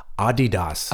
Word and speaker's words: Adidas: Yeah, which we didn't even said in Germany is pronounced Adidas. – Adidas. – Adidas. Adidas. Not Adidas.